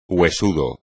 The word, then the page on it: huesudo: espagnol